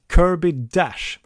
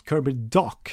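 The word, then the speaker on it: Kirby Dach: Men, och jag säger att de tar en forward bara för att Kirby Dash, eller Kirby Dock som man ska uttalas, finns tillgänglig i 8:e valet.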